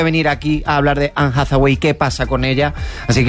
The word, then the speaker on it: Anne Hathaway: Anne Hathaway. – Eva, ¿está bien dicho? Eva, está perfectamente dicho.